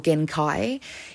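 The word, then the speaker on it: Gençay: Now, I'm going to say it like that because Spanish coverage in Colombia, which I watched, they said Ramazan Genke um, and or Genkai. It's G E N C A Y, but the C has that little kind of symbol of it.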